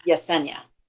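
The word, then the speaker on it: Yesenia: I think it's a silent J. – Jasenia. – Like Jorge. It's Jasenia. – Jasenia.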